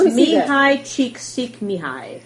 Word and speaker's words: Csikszentmihalyi: Pronounced Mihai Csikszentmihalyi.